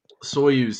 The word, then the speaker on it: Soyuz: So Soyuz.